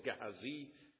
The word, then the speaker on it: Gehazi: I'm convinced most of the Hebrew scholars would say Gehazi, so there I am.